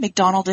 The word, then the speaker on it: McDonald: McDonald is spelled M-C-D-O-N-A-L-D all over social media.